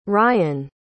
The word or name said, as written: ryann